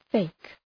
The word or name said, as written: fake